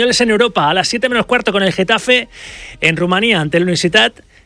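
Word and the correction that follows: Universitatea Craiova: No, es que se pronuncia Universitatea, Craiova.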